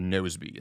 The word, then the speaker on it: Nozbe: I use a task manager called Nozbe. It's spelled N-O-Z-B-E.